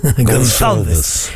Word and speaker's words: Gonçalves: Perché non lo vedo più avanti come esterno, al massimo dietro la punta, se metti Gonçalves, che tu pronunci molto meglio di me. – Gonçalves. Hanno dentro il fado nella loro è perfetto nella pronuncia di Gonçalves, non voglio rovinarlo.